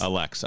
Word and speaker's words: Allexe: My name is pronounced Alex. – Alex, I'm sorry. – And no, it's okay because it's spelt A-L-L-E-X-E. So most people do want to pronounce the E at the end of it or pronounce it in a very different way.